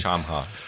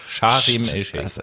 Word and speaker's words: Sharm el-Sheikh: Nicht Schamha. Sharm el-Sheikh.